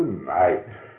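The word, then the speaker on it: Knight: Ну, так как он был англичанин, писал по-английски, пишется он, конечно, совсем книжек, начинается с буквы К, А, И, Л, и пишется он таким образом. А произносится Найт.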